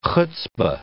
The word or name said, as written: hutzpa